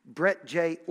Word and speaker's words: Broetje: Brett J. Orchards, B-R-O-E-T-J-E.